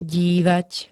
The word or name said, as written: dívať